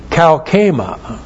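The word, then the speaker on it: kalkema (καύχημα): He has and holds kalkema. K-A-U-C-H, long E-M-A, kalkema.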